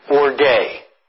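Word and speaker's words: orge (ὀργή): The word is pronounced in Greek, orge. And it sounds a little bit like our English word orgy.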